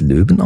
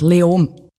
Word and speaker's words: Leoben: Und da gibt es die Stadt Leoben. Ich spreche sie wahrscheinlich falsch aus. Spricht man es Löwen aus? Wie spricht man das überhaupt aus? – Fast kein Konsonant. Leom.